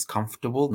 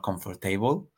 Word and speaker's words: comfortable: And then we talk about comfortable, which we need to learn how to pronounce is comfortable, not comfortable. So there's no table in comfortable.